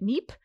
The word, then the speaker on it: Kneipp: It's spelled K-N-E-I-P-P. I was calling it Kneipp. That is incorrect. A friend who speaks German told me it's Kneipp.